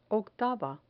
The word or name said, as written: octava